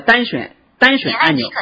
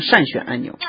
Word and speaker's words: 单选: 那么在这个它的上面它有两个单选按钮啊，它这里读错了，实际上叫单选按钮，它是个多音字，它会读成善选按钮。